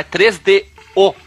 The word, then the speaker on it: 3DO: Tem podcast brasileiro que xinga quando as pessoas pronunciam errado e o cara fala 3DO e não tem acento no O na versão brasileira. Então é 3DO.